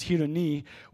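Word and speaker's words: hedoni (ἡδονῶν): And in the Greek, this is how it's pronounced as hedoni, which is where we derive our English word of hedonism.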